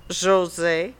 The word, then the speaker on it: Jose: Because my name in French is pronounced Jose.